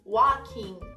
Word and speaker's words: walking: Andando, o L também é mudo. Walking, walking.